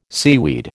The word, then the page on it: seaweed: American English